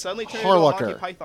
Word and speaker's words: Harlacher: Harlocker, is that how you say it? – Yeah. Hey, Jet, can I borrow your fedora real quick? – Oh, God damn it! You're doing this bit? Yes. Fucking... – Yes. Let me put on my fedora real quick. Well, actually... Yes. It is pronounced Harlocker.